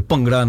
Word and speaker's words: Bhangra: Yeah, so we've all come up from, you know, times where it was all about, you know, the Bhangra nights and, you know, sorry, let me pronounce it properly, Bhangra nights, right, and the Asian nights and, you know, all these great things.